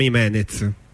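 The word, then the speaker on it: Jiménez: però è scritto J-E e quindi Jimenez diventa Jimenez, Jimenez, e c'è chi rievoca lo scrittore spagnolo, chi altri personaggi esistenti.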